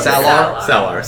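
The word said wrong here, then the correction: Saylor: Sorry. – Sailor. – Get it right.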